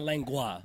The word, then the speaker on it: lengua: Is that lengua? Because it's not lengua. Lengua. It is lengua.